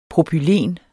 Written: PROPYLEN

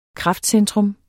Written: kraftcentrum

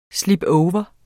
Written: slipover

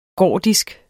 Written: gordisk

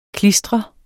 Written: klistret

